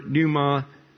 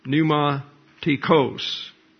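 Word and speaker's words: pneumatikōs: Note the ending of the word pneumatikos, not kos, but kos. That's an omega you've got now. And the omega is pronounced like an English long O. Pneumatikos.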